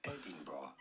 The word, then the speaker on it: Edinburgh: Edinburgh. – Edinburgh. – Edinburgh. – Edinburgh. – Edinburgh. – Edinburgh. – Edinburgh.